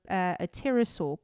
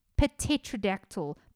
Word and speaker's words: pterosaur: The men's description of the bird matched that of a pterosaur. Guys, that word always puts me off. You know, pterodactyl, pterosaur. Why is there a silent P in front of all of the flying reptilian things?